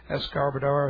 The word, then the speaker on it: Escobar: Their last names are Escobar, spelled, pronounced E-S-C-O-B-A-R, Escobar.